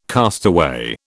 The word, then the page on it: castaway: British English